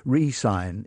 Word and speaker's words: re-sign: And again, if you're pronouncing them, they will be quite clearly different because re-sign is two syllables and resign, the first one is the vowel is almost lost.